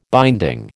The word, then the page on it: binding: American English